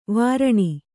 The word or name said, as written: ವಾರಣಿ